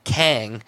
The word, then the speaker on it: Qeng: And then if you take a good close look at Avengers Tower, Stark Tower, it doesn't say Stark, it says Kang, Q-E-N-G, but it's pronounced the same way.